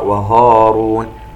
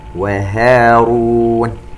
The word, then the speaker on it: هارون: وننتبه إلى كلمة وهارون، نرقق الهاء ولا نقرأ وهارون. وهارون، وهديناهما الصراط المستقيم، سبق وأن تكلمنا عن كلمة المستقيم، نركز على أن نتلفظ بالسين سين.